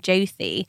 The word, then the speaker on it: Jyoti: So this name, Jyoti, I think what's interesting about it is it's a thi at the end. So it's spelt J-Y-O-T-I, but it's thi at the end.